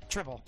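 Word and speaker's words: Tripel: I was trying to pronounce it correctly. – No, that's how you pronounce it. That's correct. Triple.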